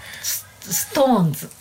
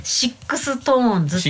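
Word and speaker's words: SixTONES: ストーンズ。 – ストーンズ。 – うん、ストーンズじゃなくて。 – ストーンズ。 – ストーンズ。 – シックストーンズって書いて。 – あれストーンズって。 – ストーンズって読む。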